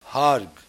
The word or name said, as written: Harg